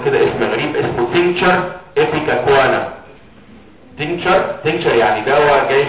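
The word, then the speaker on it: Ipecacuanha: واسمه ايبيكاكوانا والاتش لا تنطق يعني عشان برضه تشوفوا محدش يزعلك في حاجه.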